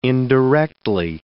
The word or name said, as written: indirectly